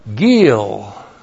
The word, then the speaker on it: gil: And then we have gil. G long I L. Gil.